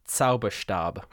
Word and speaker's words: Zauberstab: Just, I'm going to pronounce it just because I love that in the German language, the Z has the TS sound. It's Zauberstab.